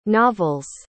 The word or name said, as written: novel's